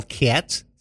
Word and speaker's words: Katz: I'm Carol Katz, spelled with a K and a Z. That's KZ, and I'm crazy for cats.